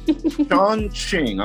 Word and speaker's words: Chongqing: Chongqing, I'm saying that correctly, right? – Yes.